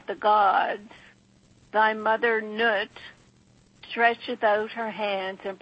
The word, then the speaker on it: Nut: And let's pronounce Nut properly. The mother, Connie, is Nut.